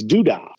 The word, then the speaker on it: Duda: and that is spelled Z-I-P-I-T-Y apostrophe S garage. And where that comes from is, you pronounce my last name, it's Duda. So you put that Zippity in front of the Duda, and you've got Zippity Duda Day.